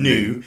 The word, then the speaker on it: GNU: But it's a lightweight alternative to the more commonly used GNU. Did I say that right? – They usually say new.